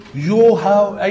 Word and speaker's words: يهوه: هم معظم العلماء قالوا لا ده بيتنطق كده يهوى، ممكن يتنطق يوها اي طريقة تانية يعني، فهم قال لا غالبا هو ده نطق الصح.